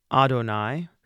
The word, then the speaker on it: Adonai: When we speak it, we say Adonai, but in this chant, we're going to break it up into one extra syllable at the end. Adonai, Adonai.